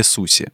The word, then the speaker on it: суси: Суши или суси? На самом деле правильно произносить суси, но это в японском языке, так как угу.